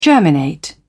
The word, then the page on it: germinate: British English